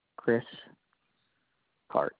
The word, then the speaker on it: Chris Karch: K-A-R-C-H. – Okay, you pronounce it Karch, correct? – Yes.